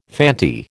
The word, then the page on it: Fanti: American English